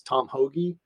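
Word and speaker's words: Tom Hoge: Now I'm going to go with a complete dark horse, even though I pronounce the guy's name Tom Hoag, but everybody says Tom Hoagie. But if you're from New Jersey and you are South Jersey where you grow up, that's not how you spell Hoagie.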